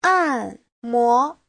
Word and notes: 按摩: an4mo2

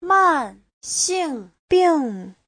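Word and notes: 慢性病: man4xing4bing4

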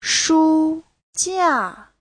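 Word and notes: shujia: shu1jia4

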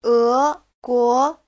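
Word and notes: eguo: e2guo2

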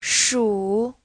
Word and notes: shu: shu3

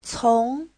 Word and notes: 从: cong2

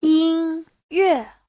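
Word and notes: yinyue: yin1yue4

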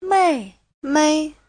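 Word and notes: 妹妹: mei4mei5

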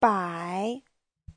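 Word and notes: 摆: bai3

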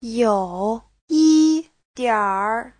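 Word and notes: youyidianr: you3yi1dianr3

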